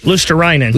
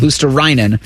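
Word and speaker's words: Luostarinen: Lustarinen. – Lustarinen.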